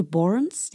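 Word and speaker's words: Børns: Borns, kennst du Borns, die Band? – Borns? B und das O ist so durchgestrichen.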